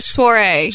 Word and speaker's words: Soiree: She gives me that look. Sorority. Is that how you say it? – Sure. Sweetheart Soiree. – Say it. – Soiree. – Did I say it right? – That got it.